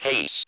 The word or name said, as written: haste